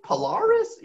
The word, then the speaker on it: Polaris: Am I saying that right? Polaris? – Yeah.